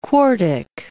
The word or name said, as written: quartic